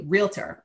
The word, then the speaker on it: realtor: Let me say it correctly, realtor. I used to own and run a real estate company for a while, and my husband used to always correct me, realtor.